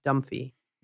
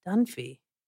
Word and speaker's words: Dunphy: Anyway, so Jay can't pronounce Dunphy. He keeps calling him Dumphy. And I remember when I first saw Outside Providence, I was like, Dunphy.